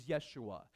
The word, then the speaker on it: Yeshua: Joshua in Hebrew is Yeshua. There's no J in Hebrew, so the way you pronounce it is Yeshua.